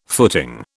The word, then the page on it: footing: British English